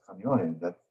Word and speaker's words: Kaneohe: Yeah, I know what I pronounce it as. It's Kaneohe. But if you are Ma'a to that, if that's how you pronounce Kaneohe, that's you know, that's your kuliana.